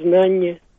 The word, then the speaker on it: знание: Очень часто йод не произносится. Ну, например, братья, говорим братья. Знание, говорим знание.